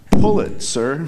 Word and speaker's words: Pulitzer: Um, th- they explain very carefully at the ceremony that it's like Pulitzer.